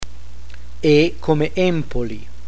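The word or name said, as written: E come Empoli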